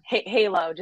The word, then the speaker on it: Heylo: But I did read that Pioneer Intelligence, the data company, labeled Heylo, I think recently labeled Heylo as one of the country's top cannabis brands. So congratulations. That's amazing. – Heylo, just to Heylo.